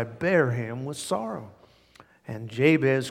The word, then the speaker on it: Jabez: This man's name is pronounced Jabez.